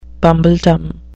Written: bumbledom

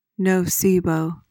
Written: nocebo

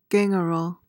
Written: gangrel

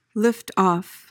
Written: lift-off

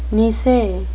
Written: nisei

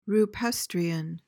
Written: rupestrian

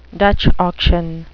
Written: dutch auction

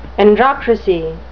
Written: androcracy

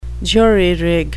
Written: jury-rig

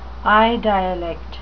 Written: eye dialect

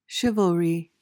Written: chivalry

